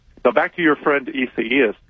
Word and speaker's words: Isaias: But the one, Isaias, is that how it's pronounced? – Isaias, that one's back.